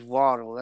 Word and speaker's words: Waddle: I wish y'all would say his name is Waddle. That's why he does the penguin dance when he celebrates. He waddles like a penguin because his last name is Jalen Waddle.